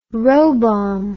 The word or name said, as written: robomb